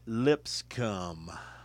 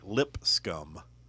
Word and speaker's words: Lipscomb: Lipscomb. Right off 12th Avenue South. – It's pronounced Lipscomb. – Lipscomb. – Sound it out. Lipscomb. – It's pronounced like lip scum.